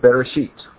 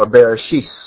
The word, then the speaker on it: Bereshit: It's called Bereshit, or Bereshis. The Ashkenazics pronounce that last letter as an S instead of a T.